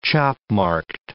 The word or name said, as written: chop-marked